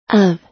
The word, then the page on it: of: American English